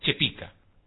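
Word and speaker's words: Chépica: ¿Vio que cuando hablábamos recién de cómo se dice Chepica o Chipica? – Sí. – Se dice correctamente, es Chepica.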